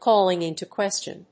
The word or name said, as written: calling into question